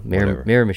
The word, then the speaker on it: Miramichi: I'm not into that, but no, Miramichi. – Miramichi.